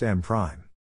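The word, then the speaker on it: M′: The last M is denoted by M apostrophe, and is pronounced M prime.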